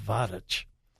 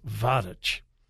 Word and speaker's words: Vatici: The youth camp that I have spoken at so many times is right outside of a tiny little village called Vatich. It's spelled V-A-T-I-C-I, but it's pronounced Vatich.